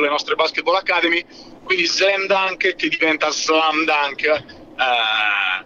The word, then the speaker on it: Slums Dunk: La pronuncia è Slam Dunk perché Slam vuol dire baraccopoli.